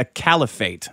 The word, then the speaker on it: caliphate: So they're running a caliphate.